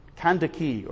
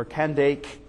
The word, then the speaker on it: Kandake: Kandake, or Kandake, as either way, you can pronounce it either way.